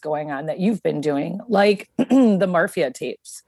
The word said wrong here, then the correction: Marfa: I probably said that incorrectly. I keep Marfa.